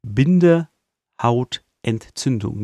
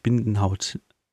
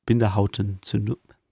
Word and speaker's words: Bindehautentzündung: Und genau, er hat den Bindehautzündungsfluch benutzt. Bindehautentzündungsfluch, sorry. Manchmal setzt man einfach die Sätze, die Silben falsch. Bindehautentzündung, nicht Bindenhaut, Bindehautentzündung.